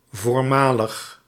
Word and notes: précédent: /pʁe.se.dɑ̃/; France (Paris)

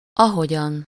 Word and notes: as: English

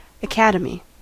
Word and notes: academy: IPA: /ə.ˈkæd.ə.mi/

